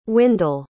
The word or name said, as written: wyndell